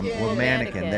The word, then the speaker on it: Womanikin: Oh, Womanikin, like a womanikin. There you go. There, that's how you say it.